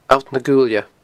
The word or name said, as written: Allt na Guailne